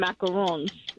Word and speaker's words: macarons: Well, there's two different kinds. There's macaroons and macarons. They're two totally different things.